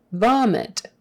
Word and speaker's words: vomit: Vomit with a V, not a B. V. Vomit. The I is short. It. Don't say vomit. That will give you away. Vomit. It has to be a short I.